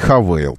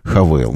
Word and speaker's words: Haval: Бренд, несмотря на то, что пишется Haval, читается Havail. Так настаивает российский офис компании Havail называть его.